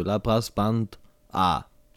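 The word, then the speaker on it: LaBrassBanda: La Brass Band A. – La Brass Banda. – War das jetzt richtig ausgesprochen, Nuspel? – Hat er das? Ja, das hat er.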